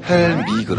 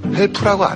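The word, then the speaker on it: help me: 헬미 그러죠. 헬프라고 안 해요. 피 발음 안 합니다.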